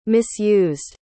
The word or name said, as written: misused